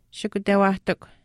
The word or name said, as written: shikutewaahtikw